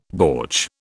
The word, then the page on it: bortsch: British English